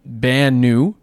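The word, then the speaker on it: Banu: And that's how you say it, the Bannu.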